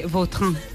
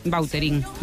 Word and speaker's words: Vautrin: Vautrin. Bueno. Pero aquí decimos bueno, Bauterín, Miriam. – Bauterín. Claro, sí, sí. ¿No? Pues... – Es que si me pregunta exactamente cómo se pronuncia, pues así, claro, pero sí. Yo ya soy Bauterín. – Ya. – Está españolizado total.